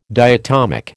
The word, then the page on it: diatomic: American English